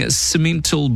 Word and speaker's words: Simmental: 20 yearling Simmental bulls,